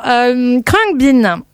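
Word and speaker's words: Khruangbin: Comme ça se prononce pas du tout. Alors Krungbin.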